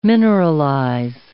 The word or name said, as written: mineralize